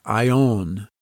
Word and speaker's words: aion: The Greek word for world is aion. In English, it's spelled A-I-O-N.